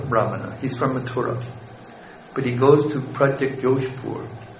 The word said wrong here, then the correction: Pragjyotishpur: Pragjyotishpur, correct.